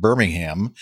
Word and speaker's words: Birmingham: Eastern Airways uh, E170 at Birmingham on uh, that's Birmingham, England.